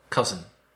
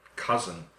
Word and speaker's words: cousin: And also be careful with the pronunciation of that word, listeners, because the spelling is a little bit difficult and doesn't really explain how to pronounce it. C-O-U-S-I-N, which a Spanish speaker may say, cousin.